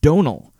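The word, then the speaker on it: Domhnall: Donal.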